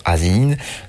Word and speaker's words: Asinine: Ça s'écrit A-S-I-N-I-N-E. Soit je vous le fais à la française et à la bonne prononciation Azinine, soit j'essaye un truc qui sonne un peu mieux comme Asinine, soit je le fais à l'anglaise en Asinine, Asinine.